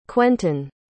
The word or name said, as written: quentin